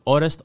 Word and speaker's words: Orest: Orest, yeah. – That's what I thought it was. What's your last name? Yeah, I know it's got every letter possible. – Omeliukh. Orest Omeliukh.